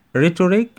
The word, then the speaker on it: rhetoric: rhetoric اللي احنا قلنا السيل الأخيرة بتنطق K. rhetoric طبعا تقولش rhetoric لا rhetoric rhetoric خلاص كده.